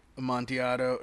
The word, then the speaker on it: Amontillado: And then would you from there start a Amontillado? Did I say that right? – Right. That's right.